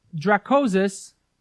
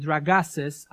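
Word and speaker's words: Dragases: He said, allowing Dracosus... Now, Litch was a good American, so he butchered his name. No offense. Allowing Dracosus, which is Constantine Dragasus, allowing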